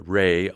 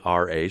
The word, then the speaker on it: Ra: Well, the name that's associated with the sun is Re, R-A, sometimes pronounced Ra.